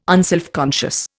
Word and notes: unselfconscious: Indian

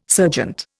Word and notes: serjeant: Indian

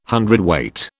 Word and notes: hundredweight: British English

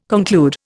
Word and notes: conclude: Indian

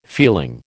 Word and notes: feeling: American English

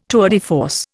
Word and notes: tour de force: Indian

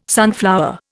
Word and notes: sunflower: Indian

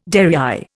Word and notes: derrière: Indian

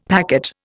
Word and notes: packet: Indian

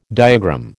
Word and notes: diagram: American English

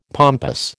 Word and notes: pompous: American English

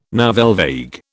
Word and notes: nouvelle vague: British English

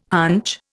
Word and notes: hunch: Indian

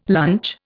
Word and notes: lunch: Indian

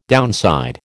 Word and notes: downside: American English

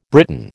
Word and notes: Briton: American English

